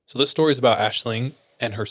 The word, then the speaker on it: Aislinn: L-I-N-G? – Says, well, how she spells it is A-I-S-L-I-N-N. And then she did like a pronunciation thing. And it's A-S-H-L-I-N-G. – Huh. – It's an Irish word for dream.